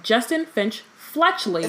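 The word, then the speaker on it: Justin Finch-Fletchley: Justin Finch Fletchley.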